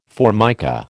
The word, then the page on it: Formica: American English